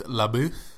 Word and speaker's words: LaBeouf: Andrew, I believe it's LaBeouf.